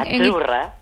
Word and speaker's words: Azzurra: Azzurra. – Azzurra. Mira, ¿ves? – Azzurra. Es que... Azzurra. Sí, como porque pizza. La Z no es la Z como decimos en catalán tan sonora, no.